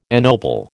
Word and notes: ennoble: American English

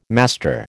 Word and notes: master: American English

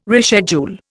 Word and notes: reschedule: Indian

